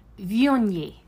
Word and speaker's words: Viognier: It would be pronounced Viognier.